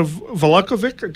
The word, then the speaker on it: Valakovic: So my guests tonight are my fellow TNT host, Jana Valakovic. Did I say that right? – Valakovic. – Thank you. I got the accent wrong.